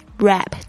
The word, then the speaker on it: wrap: Number three, wrap, W-R-A-P, rap，注意一下 W 这边不发音，有很多 WR 开头的声音开头的字呢都是不发 W 的声音，比如说像 write 写字这个字 WRITE 这个也是不讲 W 的声音。